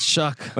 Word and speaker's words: Shough: Shuck is pronounced, but Shough. – Yeah, it's Shuck.